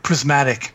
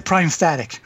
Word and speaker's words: Prismatic: He would play Illusionist, and he would always mispronounce Prismatic. He would say Prime Static. Prime Static Sphere! Prime Static Spray!